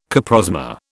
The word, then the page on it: coprosma: British English